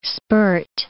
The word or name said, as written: spurt